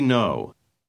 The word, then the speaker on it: know: Recuerde que la letra K no se pronuncia. – We know.